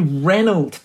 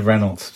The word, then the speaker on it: Renault: It's Mary Renault, Dominic. – Yeah, Mary Renault. Shocking. – It really is Mary Renault. – Of all the people to affect a French pronunciation, I would be the last that anyone would expect.